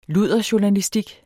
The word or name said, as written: ludderjournalistik